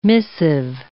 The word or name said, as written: missive